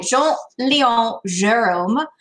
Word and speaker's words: Jean-Léon Gérôme: He was convinced after he saw a copy of a 19th century painting by Jean-Léon Gérôme called With a Turned Thumb. – I assume that was perfect French pronunciation. – It was.